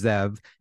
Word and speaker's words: Zeev: Uh, also, the first name is actually pronounced Zev, and I want you to call me Zev, not Dr. Neuwirth. So, um, so just, uh, and it's spelled with two E's. So you phonetically, you're correct, but it's Zev.